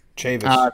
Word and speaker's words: Chavis: I mean, Chavis, am I saying that right, Jim? I saw Flaherty struggling with that, too. – Chavis.